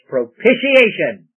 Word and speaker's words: propitiation: Some people have a hard time with that word. It's murdered sometimes. It's propitiation.